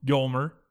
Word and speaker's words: Yolmer: Yolmer.